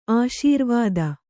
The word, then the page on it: ಆಶೀರ್ವಾದ: āśīrvāda